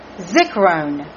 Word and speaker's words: Zikrone: Zik. Zik-R-O-N-E. Zikrone. Putting emphasis on the R-O-N-E. Zikrone.